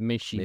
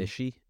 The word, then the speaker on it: Michy: I've always called him Batshuayi, so I don't know how to pronounce M-I-C-H-Y as a first name. – I think your second pronunciation was correct. I think it's Mishi. – Mishi?